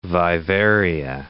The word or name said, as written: vivaria